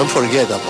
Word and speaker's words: unforgettable: Que es unforgettable. Unforgettable. Me salió como en inglés raro. Unforgettable. Unforgettable. Unforgettable. Ellos pronuncian la T como la R, ¿ya?